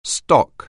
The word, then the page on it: stock: anglais